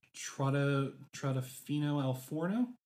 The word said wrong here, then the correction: Trattoria al Forno: Trattoria al Forno. I took a picture of the restaurant. – Trattoria al Forno. That's it.